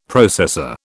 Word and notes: processor: British English

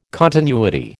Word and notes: continuity: American English

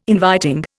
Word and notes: inviting: Indian

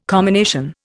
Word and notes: commination: Indian

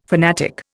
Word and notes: fanatic: Indian

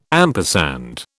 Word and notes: ampersand: British English

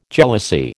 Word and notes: jealousy: American English

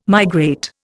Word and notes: migrate: Indian

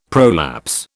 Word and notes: prolapse: British English

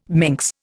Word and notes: minx: Indian

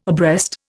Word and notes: abreast: Indian

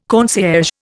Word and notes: concierge: Indian

